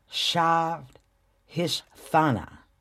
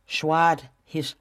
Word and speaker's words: Svadhisthana: And guys, don't kill me if I mess up the Sanskrit name, but it's Shvadhisthana. Oh, I didn't say that right. Shvadhisthana. I'm going to spell it for you. S-V-A-D-H-I-S-T-H-A-N-A. Shvadhisthana? Working on it, okay?